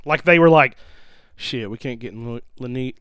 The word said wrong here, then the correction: Linnea: Oh my, I can never say her name. – Lanita. Suzanne. – Linnea Quigley.